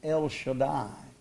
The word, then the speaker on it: El Shaddai: I said, well, the correct pronunciation for that, as far as I know, is El Shaddai.